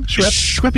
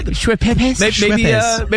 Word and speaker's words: Schweppes: Maybe it's Schweppes. How do you say that? – Schweppes. – Schweppes.